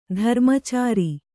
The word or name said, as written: ಧರ್ಮಚಾರಿ